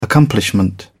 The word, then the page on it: accomplishment: British English